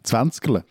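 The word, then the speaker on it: Zwänzgerle: Und zwar heißt das Zwenskalle. Ich habe es mit Sicherheit falsch ausgesprochen. Du kannst es gleich korrigieren. Erklär uns das mal. – Das wäre jetzt eher ein schwäbischer Brauch, wenn es Zwenskele. Zwanskele.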